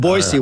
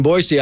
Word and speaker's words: Boise: Is that how you pronounce it? – Yeah, it's Boise, Idaho. – Wait, what are you saying? Boise? – Boise. – I say Boise. – It's Boise. – Boise? – Yeah, Boise.